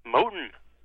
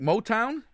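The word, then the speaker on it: Mohnton: Moton. – Like Motown? – Close. It's M-O-H-N-T-O-N. – Huh? – Moton. – There's a silent N in there.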